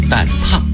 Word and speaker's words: 蛋挞: 蛋挞没有秘密，哎，生活当中呢，很多朋友习惯性的读成蛋挞哈，但是它的准确读音呢，确实是蛋挞，因为一个提手旁，一个发达的达，这个字呢，只有挞这一个读音，是用鞭子或者棍子打的意思啊，鞭挞就是这个字哈。